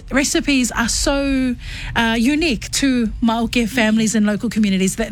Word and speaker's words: Mauke: And they pronounce it as Mauke, ne? So like Mauke, nice.